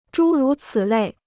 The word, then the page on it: 诸如此类: zhū rú cǐ lèi